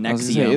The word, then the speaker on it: NXIVM: The first one was about this so-called self-help group called Nexium. It's spelled NXIVM, but they were saying Nexium.